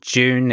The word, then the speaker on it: JW4E: Last year, I played a track by a chap called June, is how I'm going to pronounce it. It's spelled J-W-4-E.